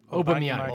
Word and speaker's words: Aubameyang: Aubameyang.